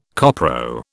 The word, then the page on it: copro: British English